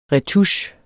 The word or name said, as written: retouche